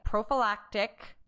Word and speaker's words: prophylactic: is you had pretty recently undergone a prophylactic double mastectomy.